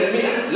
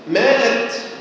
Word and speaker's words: مئة: عندما تقرأ كلمة مئة لا تنطق الألف، يبقى اللي بيقول خمسمائة مخطئ، اللي يقول مائة جنيه مخطئ، مائة كتاب طبعا هو ليه بيقول ده؟